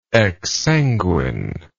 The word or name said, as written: exsanguine